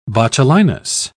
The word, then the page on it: botulinus: British English